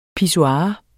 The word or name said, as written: pissoir